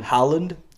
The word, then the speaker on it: Haaland: How do you pronounce it, I mean? – Haaland. – Haaland. – Yeah. I think.